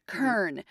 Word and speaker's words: kern: This is really interesting, Kern. – And if you didn't know how to pronounce that, the next word that rhymes with it is pronounced churn, right? – Yeah.